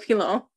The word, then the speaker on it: Pilon: Kalayla Pilon, which is P-I-L-O-N. P as in pipe, I-L-O-N.